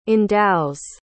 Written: endows

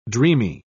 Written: dreamy

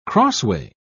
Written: crossway

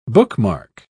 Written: bookmark

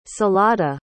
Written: salada

